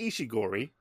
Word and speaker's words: Ishiguro: It's Nobuo Ishiguro. Ishiori. I can't fucking read. It's Ishiori. It's Ishiguro. The print was just very small.